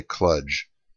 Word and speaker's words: kludge: and that is the pronunciation of the word kludge, and I say kludge, K-L-U-D-G-E.